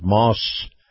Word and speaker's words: Maus: is brought to you by our friends at Moss Acura, that's spelled M-A-U-S.